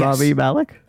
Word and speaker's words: Rami Malek: And I can't pronounce the actor's name, because... – Rami Malek? – Yeah, yes. Rami Malek.